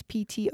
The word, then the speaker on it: Ptolemy: I think it's pronounced Ptolemy, but it's Ptolemy. I think the P silence.